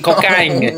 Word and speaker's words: Cocagne: C-O-C-A-G-N-E. Cocaine. – No way. That's good. Cocaine, New Brunswick. It's spelled like Cocagne, but it's in Kent County.